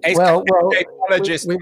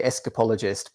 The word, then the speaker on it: escapologist: New Escapologist Magazine. – Yes. With my English accent, I say escapologist, but I know a lot of Americans say escapologist.